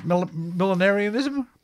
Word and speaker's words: millenarianism: Well, this also ties in with a little bit of, remember, it's 1095, you pronounce it, millenarianism? – Millenarianism, yes.